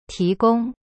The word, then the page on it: 提供: ti gong